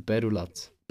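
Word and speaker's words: Perroulaz: Nein, nein, wenn ihr wirklich gute Subs wollt, also no Werbung hier, geht zu Perulatz. Perulatz Nutrition heisst das. Oder Perula eigentlich. Perula.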